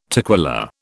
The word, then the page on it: toquilla: British English